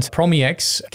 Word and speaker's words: PromEx: But this is PromEx, or PromEx, as I like to pronounce it, because of that underscore. And PromEx, can you tell us a bit more about that, what that is, the library? – Sure thing.